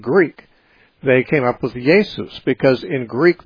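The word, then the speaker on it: Jesús: If you're a Spanish speaking person, you say Jesus, which is spelled the same as Jesus, but pronounced differently because in Spanish they pronounce the J as an H.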